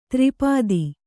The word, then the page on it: ತ್ರಿಪದಿ: tri padi; Kannada